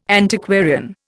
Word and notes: Antiquarian: Indian

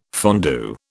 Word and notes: fondue: British English

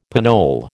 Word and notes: pinole: American English

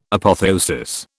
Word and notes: Apotheosis: British English

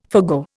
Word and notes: forgo: Indian

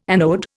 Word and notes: anode: Indian

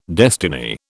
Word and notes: destiny: British English

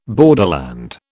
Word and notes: borderland: British English

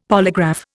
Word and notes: polygraph: Indian

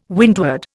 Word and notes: windward: Indian

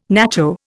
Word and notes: nacho: Indian

